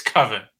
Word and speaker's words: coven: Coven, Google is definitely telling me it's Coven.